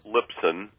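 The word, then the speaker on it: Lipson: Well, all you have to do is go to Charles Libson, and my last name is spelled L-I-P as in Paul, S-O-N, okay.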